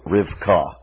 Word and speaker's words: Rivka: The name Rebekah, you would pronounce it in Hebrew, Rivka, and the name of her brother, Lavan.